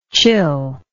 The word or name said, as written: chillness